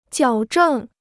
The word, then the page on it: 矫正: jiǎo zhèng